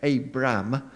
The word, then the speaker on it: Abram: When Abram, note how I pronounce that, Abram, was 99 years old, the Lord appeared to Abram and said to him, "I am God Almighty.